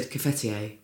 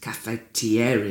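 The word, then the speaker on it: cafetière: and my mum had always said cafetier. – Cafetier. – And she's not from, you know, the Estuary or South London. She just mispronounced it cafetier very confidently. And so I came to London saying that and was... really bullied hard. – Ah. – And was trained cafetiere Henri.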